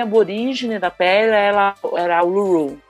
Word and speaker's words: Uluru: Uluru, a pedra lá do meio.